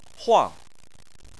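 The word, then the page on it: huang: tone 4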